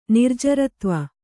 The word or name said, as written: ನಿರ್ಜರತ್ವ